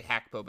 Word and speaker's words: Gakpo: It's Gakpo. It's actually Hakpo, but no one calls him that.